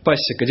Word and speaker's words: Пасек: И ну, мы подробно рассказывали о том, что странным вообще, тоже странным событием стал арест одного из заговорщиков, Пасека. Дело в том, что, ну Пасек, да, скорее всего, он же не француз, а украинец, поэтому на первом слоге ударение.